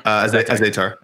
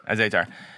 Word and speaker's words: Azaitar: What was the guy that lost as how do you say his name? The guy that lost Azaitar. – Azaitar.